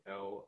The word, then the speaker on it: Loeschner: Primarily right now, just at Andrew Loeschner on Twitter. That's where I'm most active. My last name is spelled Loeschner.